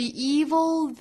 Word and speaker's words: evil: 音的 e 那前面这个 THE 变音成 v 再和后面连读呢相当于两个相同的元音相遇了同样呢在中间也是要接一个小小的 e 的发音的 the evil the evil The evil that men do, evil 这个词我们注意尾部有一个舌侧音 evil, evil,